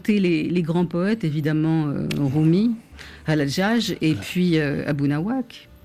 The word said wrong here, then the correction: Abou Nawas: Abou Nawas, je ne prononce pas très bien.